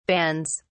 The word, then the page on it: bands: British English